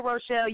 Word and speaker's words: Rochelle: Yes, Danica Rochelle,